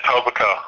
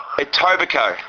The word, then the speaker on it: Etobicoke: I was born in Etobicoke. – Etobicoke.